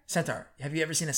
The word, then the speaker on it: centaur: You just said the right word and then you said it wrong. – What is you centaur. Just said senator. I'm like, senate, a centaur that's also a senator, a senator. – What is the word? – You said it right the first time, centaur. Center. Now you're saying center, like center for disease control.